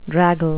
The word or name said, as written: draggle